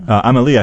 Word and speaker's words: Amelie: Little Amelie, is that how we're pronouncing it? – Amelie, I think, is the right pronunciation.